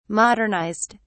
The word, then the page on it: modernized: British English